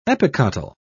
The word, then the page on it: epicotyl: British English